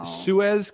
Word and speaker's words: Suez: In the Suez Canal.